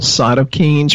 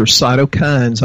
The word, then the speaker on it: cytokines: but the messenger system is something called cytokines or cytokines. I've heard it pronounced both different ways, and I'm sure we pronounce things different here in Texas, too.